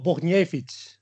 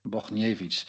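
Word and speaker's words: Bochniewicz: Nou, spreek jij, hoe spreek je hem nou goed uit? – Pavel Bogniewicz. – Bogniewicz.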